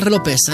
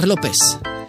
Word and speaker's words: López: Figyelj, a legújabb Jennifer López, a legalább megvan a spanyol akcentus, tudod, Jennifer López.